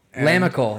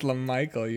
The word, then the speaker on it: LaMical: Yeah, but it's spelled L-A-M-I-C-A-L. – Lamical. – And I thought it was Lamical, right. And I pronounced that's it that right, way on the pod. Right. Someone's like, it's LaMichael, you idiot.